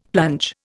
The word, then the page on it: plunge: Indian